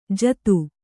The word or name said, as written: ಜಾದೂ